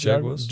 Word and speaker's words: Jaguars: Äh, die, wie spricht man es aus? – Jaguars. Jaguars. Ja.